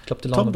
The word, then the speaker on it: DeLonge: Mit Tom DeLonge, ich glaube, der heißt nicht so Tom wie diese Kaffeemaschine, DeLonge wird ausgesprochen, ja.